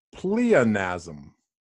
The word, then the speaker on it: pleonasm: The word of the day is pleonasm.